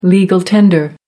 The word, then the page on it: legal tender: American English